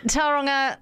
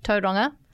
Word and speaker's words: Tauranga: Anyway, Tauranga, sorry, that's terrible pronunciation. Tauranga is going to be having their store opening.